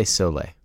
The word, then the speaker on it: Soleil: I would say Soleil.